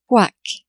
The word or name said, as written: quoique